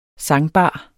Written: sangbar

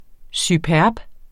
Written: Superbt

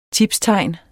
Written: tipstegn

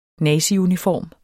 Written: naziuniform

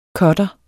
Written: cutter

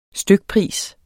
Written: stykpris

